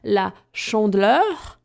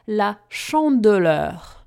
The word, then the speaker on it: La Chandeleur: Sama nazwa La Chandeleur zawiera w sobie tak zwane e caduc, które często wypada, to znaczy nie wymawia się go, dlatego słyszymy La Chandeleur, a nie La Chandeleur.